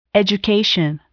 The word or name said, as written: education